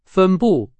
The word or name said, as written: 分布